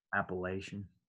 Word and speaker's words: Appalachian: Hey, out of curiosity, how do you say, Appalachian or Appalachian? – Appalachian. – Appalachian. – Yeah, but I have heard it the other way, probably from people that live there.